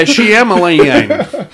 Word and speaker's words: Shyamalan: Is that not how you pronounce his name? Shyamalan? – No, it sounds like Rebel of a Dig Dog. – Yeah, Shyamalan.